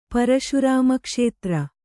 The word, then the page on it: ಪರಶುರಾಮಕ್ಷೇತ್ರ: paraśurāma kṣētra